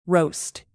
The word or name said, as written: roast